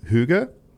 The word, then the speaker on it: hygge: It's called hygge. It's H-Y-G-G-E.